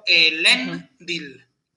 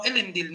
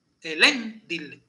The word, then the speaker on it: Elendil: Otra forma en la que puede ser fuerte la segunda sílaba y llevarse el acento, cuando termina en consonante. Por ejemplo, Elendil. Isildur. No Elendil ni Isildur. Elendil y Isildur. Porque la segunda es fuerte.